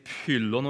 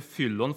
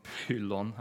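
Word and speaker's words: pyllon (φύλλον): Um, og jeg sier, jeg sier, pyllon og ikke fyllon fordi um, på gresk altså, oss, er vant med å uttale ph som f, men på gresk så var nok det en slags p-lyd med en pust etter seg. Uh, så Sapfo ville nok sagt noe slikt som pyllon her.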